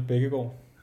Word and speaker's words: Bækkegård: So my name is Daniel Bækkegård.